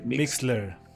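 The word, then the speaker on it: Mixlr: Y luego los podcasters, pues nos van a seguir en Spotify, en Deezer, en Apple y en Mix, ese cómo se pronuncia, Mix LR, Mixler. – Mixler, Mixler, Mixler.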